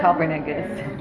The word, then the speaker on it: Copernicus: Well, first of all, it's pronounced Copernicus. – Copernicus? – Galileo. – Galileo. – They're both astronomers. – Galileo Galilei. – It's Galileo Galilei and Copernicus. I looked this up. It's fact. – Copernicus. – Copernicus.